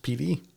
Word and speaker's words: Pittee: Yeah, his last name is spelled P-I-T-T-E-E. Google tells me it's pronounced Petey. – I would say Pity, but that's I want to say Petey.